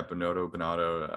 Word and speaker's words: Binotto: Yeah, Binotto.